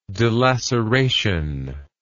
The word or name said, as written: dilaceration